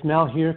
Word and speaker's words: Malheur: Well, it sounds like he isn't so sure at first, but and you're right, you pronounce it right. It's Malheur County, and he's the sheriff in that county.